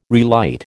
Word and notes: relight: American English

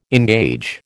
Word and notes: engage: American English

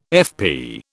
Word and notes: FP: British English

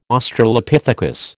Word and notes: Australopithecus: American English

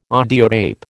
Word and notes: audiotape: American English

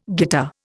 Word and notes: guitar: Indian

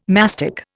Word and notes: mastic: Indian

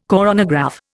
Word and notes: coronagraph: Indian

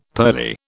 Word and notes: pearly: British English

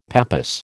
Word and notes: pappus: American English

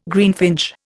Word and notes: greenfinch: Indian

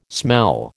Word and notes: smell: American English

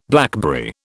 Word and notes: blackberry: British English

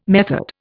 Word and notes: method: Indian

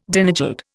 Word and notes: Danegeld: Indian

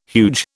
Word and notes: huge: British English

